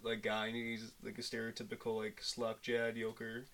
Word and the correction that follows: yokel: So slack-jawed yoker. Yokel. Oh my god. – I butchered that. – You had me say yoker too. Yokel.